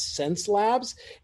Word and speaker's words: Xencelabs: And just so everybody knows, since this is audio, even though I'm pronouncing it as Xencelabs, it's actually X-E-N-C-E.